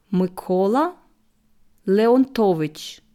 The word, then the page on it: Mykola Leontowytsch: slow